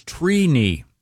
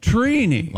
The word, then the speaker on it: Trini: The correct pronunciation is Trini. – Wow. – Trini. – Like T-R-E-E. – Like weenie. – Yeah, I is it phonetically T-R-E-E-K-N-I? – Yeah, it's, phonetically it is T-R-E-E.